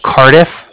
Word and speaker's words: Cardiff: The Bank of Cardiff. So it's spelled C-A-R-D-I-F-F, as in Frank Frank. So it's like car and then different.